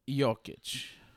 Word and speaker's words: Jokic: Because he pronounces his name like Jokic, so like... – Yeah, that's true. – The Joker. – The J is like a Y.